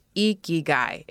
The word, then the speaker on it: Ikigai: Yes, it's pronounced Ikigai if you've heard of it before.